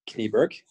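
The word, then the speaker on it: Kniberg: My name is Henrik Knieberg, but there's various pronunciations of it, and I'm not picky.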